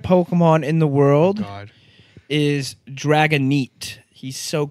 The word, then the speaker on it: Dragonite: As most of you already know, the proper pronunciation is Dratini, Dragonair, and Dragonite.